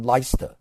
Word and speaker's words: Leicester: 德文、法文的名字错译啊，比方说他有一个叫 Leicester，他译成了李瑟斯特，啊，其实他是读成 Leicester。他说你这个英文学教授连音都读错的，这是不对的。